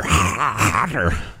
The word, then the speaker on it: Qatar: Qatar, or Qatar, as it's pronounced, is One of two is how it's pronounced.